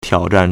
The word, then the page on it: 挑战: 挑戰; tiǎozhàn